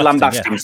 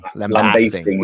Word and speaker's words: lambasting: Americans say lambasting is what somebody said to me the other day. – Lambasting. We like the like long A, lambasting.